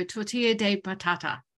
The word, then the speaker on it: tortilla de patata: Tortilla de patata. Pronounce right? Yeah.